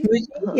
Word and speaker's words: Muji: I think Muju, is that how you say it? Muju? Muji? Muji? – Muji? Muji? – Muji? Yeah, Muji has them.